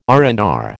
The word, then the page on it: R and R: American English